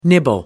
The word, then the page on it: nibble: British English